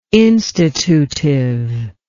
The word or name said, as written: institutive